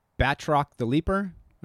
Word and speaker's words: Batroc the Leaper: That leads me to my next question, the obvious conversation between Batroc the Leaper.